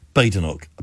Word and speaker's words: Badenoch: So every time I think, oh, I've actually got that wrong. So it's Kemi Badenoch, apparently.